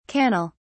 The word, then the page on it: cannel: British English